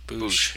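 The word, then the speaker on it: Boushh: Ah, Boosh. Is that how you say it? – Boosh. Yeah, I kept thinking Bosk because we were just talking about Bosk before we started recording.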